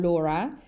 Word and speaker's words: Laura: So it's uh, Laura or Laura and then is artero, A R T E R O dot com.